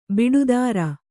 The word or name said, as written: ಬುಟೇದಾರಿ